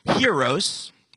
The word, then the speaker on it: hieros: In Greek here, it's hieros, rhyming with euros.